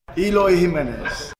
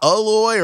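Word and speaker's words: Eloy Jiménez: Eloy Jimenez. – There it is. – Eloy. – He's Eloy. – Okay. – So not Aloy or Eloy, Eloy. So Eloy Jimenez.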